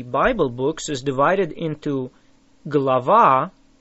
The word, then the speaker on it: глава: How do you say a chapter? Глава. Глава. Say ва. Лава. Глава. It starts with a г or g sound. Say it again.